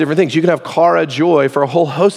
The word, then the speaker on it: chara: Greek word there is chara. C-H-A-R-A is how you spell it in English.